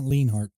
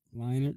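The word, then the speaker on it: Leinart: It's not Leinhart. – Leinert? – Leinert. – Leinert. Oh, okay.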